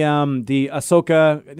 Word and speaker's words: Ahsoka: Like in the Ahsoka, no, I never say that name right.